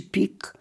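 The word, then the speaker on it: Pieck: że nie wolno teraz będzie mówić trzeci pik, bo tak właśnie czyta się nazwisko Wilhelma Pika, prezydenta czy pierwszego sekretarza partii w NRD.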